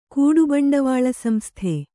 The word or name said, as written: ಕೂಡುಬಂಡವಾಳಸಂಸ್ಥೆ